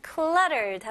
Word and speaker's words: cluttered: 자, 일단 중간에 이렇게 TT 있을 때는 미국식 발음으로는 T를 그렇게 강하지 않고 이렇게 butter, better, 뭐 이런 식으로 해야 돼요. 그래서 여기도 cluttered 보다는 cluttered, cluttered, okay?